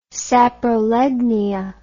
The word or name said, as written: saprolegnia